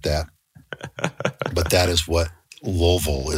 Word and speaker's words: Louisville: For example, Louisville, as I pronounce it, is called Louisville. – Louisville.